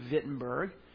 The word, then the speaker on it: Wittenberg: It was called the University of Wittenberg, or Wittenberg. It's a W. It's pronounced like a V in German. University of Wittenberg.